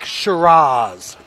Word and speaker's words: Shiraz: Black Shiraz. Did I say that right? – You did.